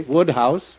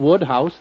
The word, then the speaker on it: Wodehouse: I'm sure that if Mr. P.G. Wodehouse were here, he'd agree. – Woodhouse. – What was that again? – Woodhouse, that's how it's pronounced.